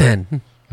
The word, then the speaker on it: Nissan: It's like, because we say Nissan, but in Japan, it's Nissan. – Yeah. – And so what happens is this guy who's part of, it's an American who's part of Nissan North America,